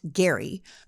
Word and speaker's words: Gerry: The term gerrymandering comes from Massachusetts Governor Elbridge Gerry, spelled Jerry but pronounced Gerry,